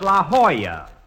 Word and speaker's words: La Jolla: Rochester, I found out that's pronounced La Jolla. It turned out to be a Spanish word.